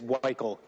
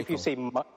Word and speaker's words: Weichel: It is Weichel. And basically, Weichel. If you say Michael but replace the M with a W, you're saying it right.